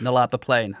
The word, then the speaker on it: Nullarbor: Is it pronounced Nullarbor? – Yeah, Nullarbor Plain.